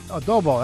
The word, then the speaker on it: adobo: Am I saying it wrong? Adobe? – Adobo, that's...